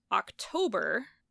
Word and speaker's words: October: But October, the emphasis is on the toe, and octopus, it's on the oc.